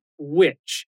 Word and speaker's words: which: para te ajudar a entender essa notícia, vou te explicar uma palavra que apareceu bastante por aqui: which. W-H-I-C-H. E não é witch de bruxa, a pronúncia é a mesma, mas a escrita e o significado são diferentes.